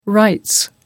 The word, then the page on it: rights: British English